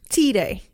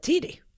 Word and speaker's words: Tiede: Familjen Tide, jag vet inte hur jag ska uttala det på engelska. – Tide. – Stava. – T-I-E-D-E. – Ja, Tide.